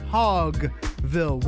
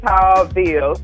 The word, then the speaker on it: Hawville: You're from the west side, but you claim Hawville, which I always have pronounced Hogville with the G. Apparently nobody else does that. – No, that's that region stuff. That's that up north stuff. It's Hawville. So shout out to all my 90s babies that went to Kristen Moore Day Camp.